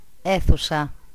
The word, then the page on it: chambre: France; [ʃɑ̃bʁ]